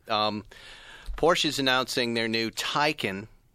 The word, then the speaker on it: Taycan: Taycan. Tie like a tie like you're wearing and a con like you are as well.